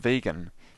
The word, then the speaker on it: vegan: Donald Watson coined the term vegan. He took the start of vegetarian and the end to form veg-an, pronounced vegan.